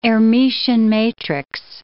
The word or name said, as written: Hermitian matrix